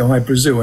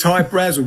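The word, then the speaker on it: Hy-Brasil: People pronounce it I Brazil, and it's Brazil. Okay. – yeah. But it's High Brazil because that's how the Irish would pronounce it, because it's it's an island that's off the southwest coast of Ireland.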